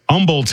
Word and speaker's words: Humboldt: Humboldt, Texas, H is silent, Texarkana, Texas, Augusta, Kansas, Concordia, Kansas, Omaha, Nebraska, Cedar Rapids, Iowa.